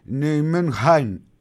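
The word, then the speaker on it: Nijmegen: Α, με πήρε ένας ακροατής, έστειλε μήνυμα, όλοι και εγώ να ημέγγεν τη λέμε την ομάδα. Η Ολλανδική όμως λέει προφορά είναι Νιουμενχάιν, είναι κάπως βαθύ το χι, δεν μπορώ να το πετύχω.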